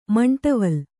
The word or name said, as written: ಮಂಟವಲ್